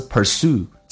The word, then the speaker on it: pursue: 一个单词 pursue, 在美式发音当中 S 加 U 一般发成 U 这个发音叫 pursue。